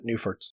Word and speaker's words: Neuferts: I never know how to say it. The Neuferts? – Yeah, Neuferts. – Neuferts data.